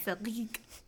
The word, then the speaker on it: Seyrig: The film steps us through the life of a widowed mother named Jean Diamant, played by Delphine Seyrig. Seyrig. Hope I said that right. I think that's right. Seyrig. – It's probably Seyrig. – Seyrig. That's way better.